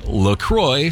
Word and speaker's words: LaCroix: See, it's a cross between La Crosse, Wisconsin, where it's made, and the St. Croix River in western Wisconsin. So it's pronounced La Croix because of that.